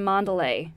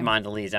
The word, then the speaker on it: Mondelez: Is it Mondelez or Mondelez? – I think it's Mondelez.